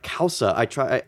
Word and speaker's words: causa: They created a dish called causa. The way I figured out how to pronounce this is it sounds like Peter Krause, the actor, but without the R.